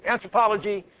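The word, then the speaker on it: Anthropologie: The retail segment consists of Urban Outfitters, Anthropology, that's the word. I always put an extra syllable or two. Anthropology, Free People, Terrain, Leaf's Daughter, and Beholden Brands, whose merchandise is sold directly through stores and catalogs and call centers and websites.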